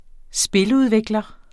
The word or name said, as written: spiludvikler